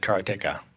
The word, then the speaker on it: Karateka: Well, I just happened to have, I watched this video with Jordan Mechner, and he said Karateka. – Yeah. No, I know, because he's right.